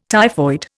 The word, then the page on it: typhoid: Indian